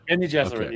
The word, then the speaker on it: Bene Gesserit: Bene Gesserit? – Bene Gesserit, yes.